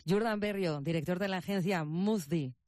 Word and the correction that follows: Muzfi: A ver si lo digo bien, Muzfi. – Muzfi.